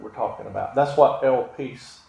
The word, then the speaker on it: elpis: If I say that right, is the English spelling is E-L-P-I-S, but the pronunciation guide gave me elpis.